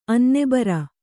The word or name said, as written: ಅನ್ನಬರೆ